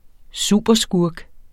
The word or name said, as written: superskurk